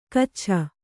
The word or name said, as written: ಗಚ್ಛ